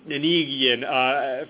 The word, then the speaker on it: Nanigian: see it, then they typically pronounce it as Nanigian forever. But if they see it written out, N-A-N-I-G-I-A-N, and then they hear it afterwards, they typically anchor to that which they see rather than that which they hear.